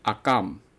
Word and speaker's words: Akame: 但是我们念是念阿卡美，但事实上罗马拼音不是这个样子的，它念阿甘，哦阿甘，它是念阿甘的哦。